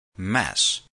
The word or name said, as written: mess